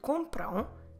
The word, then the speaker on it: compram: Because if I'm using they buy, I need to say eles compram, eles compram, and compram ends in a nasal syllable, A-M. Como se escreve compram? C-O-M-P-R-A-M. Eles compram o livro.